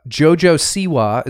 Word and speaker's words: Siwa: Cribs, by the way, with Caitlyn Jenner, JoJo Siwa, is that how you pronounce that? – Yeah.